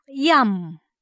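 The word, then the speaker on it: yam (ים): 单数的海读音叫做 yam yam，写起来只有两个希伯来文字母，一个是 yod 的，还有一个是 mem yam。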